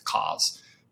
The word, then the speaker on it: COZ: Tell us a little bit about Cause. Hopefully I pronounced that correctly. – Yeah, you're good.